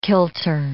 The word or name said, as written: kilter